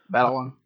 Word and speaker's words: Batalon: And you have Tom Holland, Michael Keaton, Robert Downey Jr., Marissa Tomei, John Fairview, Gwyneth Paltrow, Zendaya, Donald Glover, Jacob Batalon, I think is how you pronounce his name. – Batalon.